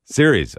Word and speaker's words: Ceres: Am I saying that right? – Ceres. – Okay, there you go. Ceres, California.